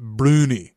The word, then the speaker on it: brownies: And in the Scottish accent, it's pronounced broony.